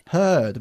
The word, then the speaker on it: heard: Que aunque se escribe igual, con tan solo la diferencia de una D, no se pronuncia igual, porque decimos to hear y en el pasado. – Hurt. – Hurt, muy bien.